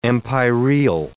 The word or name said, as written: empyreal